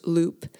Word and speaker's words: loupe: I learned a new word as I read this poem, and the word is loop, L-O-U-P-E. It's pronounced loop, and it's a term for a magnifying glass, a very strong magnifier that's used by jewelers and watchmakers.